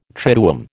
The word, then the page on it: triduum: American English